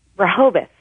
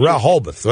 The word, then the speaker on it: Rehoboth: Am I pronouncing that right? – Rehoboth, Massachusetts. – Rehoboth.